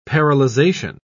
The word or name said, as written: paralyzation